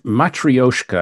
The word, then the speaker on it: Matryoshka: Matryoshka, I believe, is how it's pronounced. Matryoshka. Mm-hmm. Although I could be leaving out a syllable.